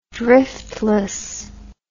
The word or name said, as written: driftlessness